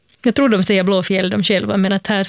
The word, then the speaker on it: Blåfield: Det som folk brukar tendera att uttala Blåfield, alltså blå, alltså en F-I-E-L-D, för att det är en gammal stavning av ordet fjäll. Så det namnet är Blåfjäll.